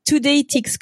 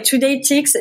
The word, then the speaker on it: Today Tix: Today Tix, comme les tickets du jour quoi. – Ouais, Today Tix, sauf que Tix ça s'écrit T-I-X.